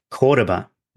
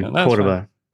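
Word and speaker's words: Córdoba: Because when Bob and I were in Cordoba, did I say that right? Cordoba? – Yeah. – Cordoba. We were in Cordoba. Right. Yeah, yeah. Soft R.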